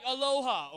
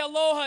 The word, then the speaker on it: aloha: You don't just say it like aloha, okay? That is not cool, all right? The way you say aloha is you say aloha.